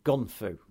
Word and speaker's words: gun-fu: And this sort of fighting style of gung-fu and all that is so... – Gung-fu? – Gung-fu. Gun-fu. Not gung-fu. I keep saying it wrong.